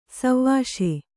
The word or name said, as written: ಸೇವಿಸು